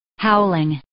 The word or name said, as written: howling